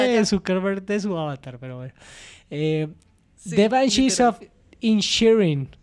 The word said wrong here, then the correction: Inisherin: Va a terminar pronunciando la película. – Este mal lo ha dicho 6 veces. – De 10.000 formas distintas. – The Banshees of Inisherin.